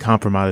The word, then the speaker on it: compromat: Is that how you say it? Compromat? – I think compromat is compromat. Correct.